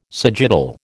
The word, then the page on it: sagittal: American English